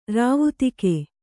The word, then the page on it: ರಾವುತೆಗೆ: rāvu tege